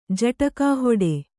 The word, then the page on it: ಜಟಕಾಹೊಡೆ: kannada